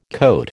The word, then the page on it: cote: American English